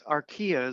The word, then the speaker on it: archaea: Yes, archaea is the way they generally pronounce it. Archaea as in archaeology or archaic, it suggests old.